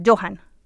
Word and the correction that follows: Joxan: Joxan. – Sí, Joxan.